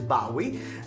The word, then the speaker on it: Bowie: Adesso invece abbiamo ascoltato David Bowie, perché si pronuncia David Bowie.